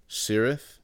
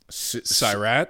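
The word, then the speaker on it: Sirât: Sentimental Value, Sinners, Syrith. – Oh, is it... Syrat? – Syrat? – Syrat. – Is that how you say it? – I think so, yeah.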